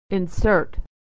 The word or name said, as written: to insert